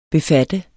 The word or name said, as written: befatte